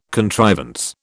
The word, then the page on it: contrivance: British English